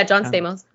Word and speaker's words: Stamos: So John Stamos actually, did I say that right? – Yeah, John Stamos.